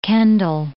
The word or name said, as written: Kendal